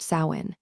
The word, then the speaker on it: Samhain: To commemorate this transition, they observed a festival called Samhain, pronounced Sawin.